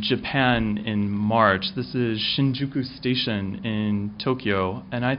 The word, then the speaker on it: Shinjuku: Shinjuku.